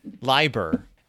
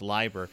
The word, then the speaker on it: Leiber: Lieber. L-E-I-B-E-R. And according to German pronunciation rules, you pronounce the second vowel in the combo, so it's Lieber. He actually went on record as saying that. He's like, Lieber, L-I-E-B-E-R, is a really common last name.